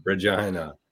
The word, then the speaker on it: Regina: Chez says the city that rhymes with fun. Regina.